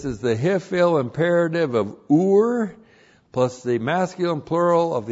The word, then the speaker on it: Ur: Ion, long U, R, Ur.